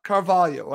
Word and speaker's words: Carvalho: And as I've been told by a friend in Spain, it's actually pronounced Carvalho, not Carvalho. Again, I'll say it again, Carvalho. That's what I've been told is the proper pronunciation of Fabio.